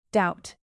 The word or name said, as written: doubt